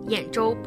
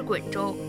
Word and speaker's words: 兖州: 他方知道兖州不是滚州，这滚字的读音是滚而不是兖，但是要皇帝认错不是那么容易的事儿啊。